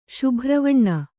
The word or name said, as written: ಶುಭ್ರವಣ್ಣ